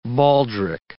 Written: baldric

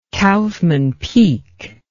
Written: Kaufmann Peak

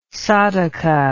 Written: sadhaka

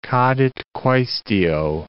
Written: cadit quaestio

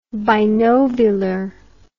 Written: binovular

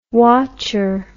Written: wotcher

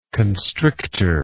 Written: constrictor

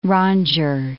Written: rondure

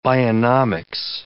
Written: bionomics

